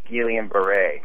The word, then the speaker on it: Guillain-Barré: Hey, I was just going to mention to you that disorder you're talking about is actually pronounced Guillain-Barré.